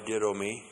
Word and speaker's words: didomi: D-I-D long O-M-I, didomi.